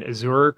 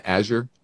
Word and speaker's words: Azure: I mean, Azure crossed $100 billion in annual revenue for the first time. – Isn't it Azure? – Azure, whatever. I don't need to know how to pronounce it.